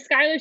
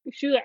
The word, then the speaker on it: Schuyler: I have seen Hamilton and It's Schuyler. – That's how her name is. Yeah, it's the Schuyler sisters, and if you look it up, it's spelt that way.